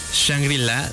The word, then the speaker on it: Shangri-La: Pero bueno, regresando a la producción, el álbum fue grabado en los míticos estudios, me corregirán si lo pronuncio mal, Shangri-La de Malibu, o LA. – Correcto, correcto.